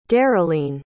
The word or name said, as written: darylene